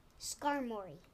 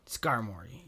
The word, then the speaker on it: Skarmory: Skarmory. – Skarmory, yes.